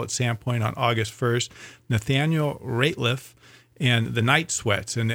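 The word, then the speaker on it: Nathaniel Rateliff: This is by Nathaniel Ratliff.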